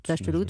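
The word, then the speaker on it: Dasht-e Lut: Quatre expéditions de 30 jours, alors chaud et sec dans le désert de Dachetlut, en Iran, c'est comme ça qu'on prononce? – Oui, Dachetlut. – Dachetlut, voilà.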